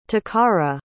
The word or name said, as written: takara